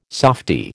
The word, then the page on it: softy: American English